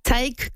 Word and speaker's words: Taïk: Taïk comme Mike, mais avec un T.